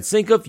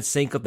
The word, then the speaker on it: Senkiw: I'm your host, Brad Sinkoff. You can follow me on Twitter, and I ask that you please do, at Brad Sinkoff. That's S-E-N-K-I-W.